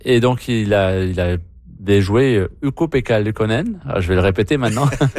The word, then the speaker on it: Ukko-Pekka Luukkonen: Newhook, hier, a déjoué Ukko-Pekka Luukkonen.